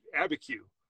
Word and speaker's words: Abiquiu: And people get all, and it, so when you say it correctly, it's Abiquiu. So it really sounds like, you're just saying the letters ABQ.